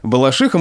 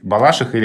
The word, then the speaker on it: Балашиха: Я помню, тогда было очень много вопросов от журналистов, как же правильно произносить, «балашиха» или «балашиха».